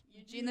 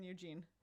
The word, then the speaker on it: Ugin: Eugene the Spirit. – That's the correct pronunciation. – Exactly. – Yes, like, I agree. – U-G-I-N Eugene.